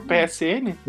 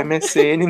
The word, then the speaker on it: MSN: Ah, número é verdade, do, de lá do Orkut e do outro PSN. MSN.